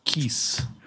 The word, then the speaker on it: Keese: Keese. – Keese, is that how you pronounce it? Keese, Kessa, I forget. Oh, well. – At least in English, I usually think it's Keese.